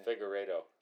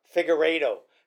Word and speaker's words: Figueiredo: Figueiredo. Figueiredo.